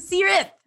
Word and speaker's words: Seerat: Welcome, Seerat.